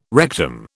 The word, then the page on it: rectum: British English